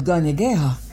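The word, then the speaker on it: Kanien'kéha: The Mohawk language is called Gonyageha, and yep, you guessed it, those G sounds are actually spelled with K's.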